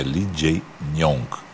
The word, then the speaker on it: Lee Jae-myung: E a gente aprendeu, eu pronunciei errado, é Lee Jae-myung.